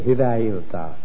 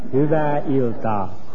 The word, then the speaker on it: Hyvää iltaa: Tell me, how do you say good evening in Finnish? – Hyvää iltaa. – Give me that again. – Hyvää iltaa. – Hyvää iltaa. – That's right.